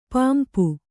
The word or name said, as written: ಪಂಪ